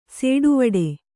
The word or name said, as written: ಸೆಟ್ಟವಟ್ಟ